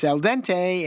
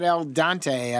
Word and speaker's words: al dente: Notice how I pronounce that? I pronounced it correctly for Gino out there who listens all the time from Milwaukee and corrects me. It's al dente. It's not al dante.